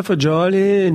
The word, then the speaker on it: fagioli: Pasta fagioli, nice. – Is it fagioli? – Fagioli, exactly.